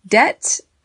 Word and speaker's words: debt: Debt has a silent B, D-E-B-T, and it is something that you owe, usually money.